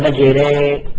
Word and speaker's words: مجراها: إذا أردت أن تقول تلفظ كلمة مجراها بالإمالة كيف تقول؟ تقول مجري مجري، تأذنها على وزن زيد، مجري، هكذا.